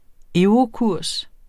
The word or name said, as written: eurokurs